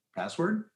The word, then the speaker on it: passwd: Password? – Isn't it P-A-S-S-W-D? – No, it's password. – Oh, okay. Well, then never mind. Not such a good story, but I remember you saying password.